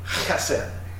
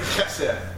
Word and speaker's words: chesed: It is chesed. Anybody want to say that? Chesed. Chesed. It's kind of just like spelled cheesed, but it's not.